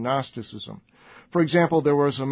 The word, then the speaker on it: Gnosticism: It was, for shorthand, called Gnosticism, with a silent G, Gnosticism,